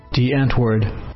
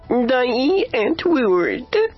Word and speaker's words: Die Antwoord: Die Antwoord. – Die Antwoord. – Die Antwoord. – Die Antwoord.